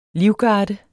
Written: livgarde

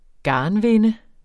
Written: garnvinde

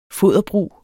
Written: foderbrug

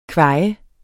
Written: kvaj

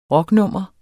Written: rocknummer

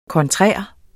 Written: kontrær

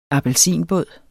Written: appelsinbåd

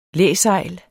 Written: læsejl

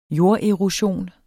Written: jorderosion

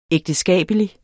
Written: ægteskabelig

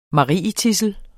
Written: marietidsel

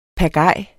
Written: PAGAJER

